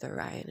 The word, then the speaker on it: Orion: So, um, but this was my short interview with Orion and about um, how he first discovered contact.